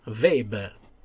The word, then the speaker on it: Weber: Quella, come diceva Weber, che è uno dei grandi autori, e mi raccomando, si chiama Max Weber, con la V come Volkswagen, perché il doppio V in tedesco si pronuncia V,